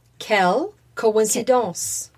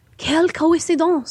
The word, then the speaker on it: Quelle coïncidence: Quelle coïncidence. – Quelle coïncidence.